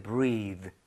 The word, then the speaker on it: breathe: 그리고 발음도 숨 쉬다는 breathe. 그리고 숨 호흡은 breath. re. 이렇게 발음이 되죠. breath.